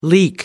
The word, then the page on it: leak: American English